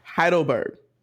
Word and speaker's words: Heidelberg: Heidelberg. That's how you say it, yep.